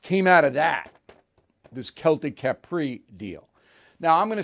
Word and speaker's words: Celtic: Celtic like Boston Celtics, but the correct pronunciation is Celtic, C E L T I C, Capri, C A P R I.